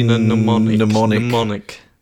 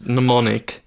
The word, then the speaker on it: mnemonic: Suggests Johnny mn- Johnny mnemonic. – Yes, silent M. Silent M followed by non silent M. Yes, mnemonic. Yes.